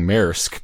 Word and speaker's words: Maersk: Well, thank you, first of all, for saying Maersk, because I was definitely afraid to pronounce that word, and I'm glad that you got it out of the way.